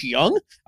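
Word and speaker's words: Jung: I thought you said Josh Young. I was like, I don't know who that is. – That's how you pronounce it.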